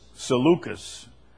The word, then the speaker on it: Seleucus: Seleucus.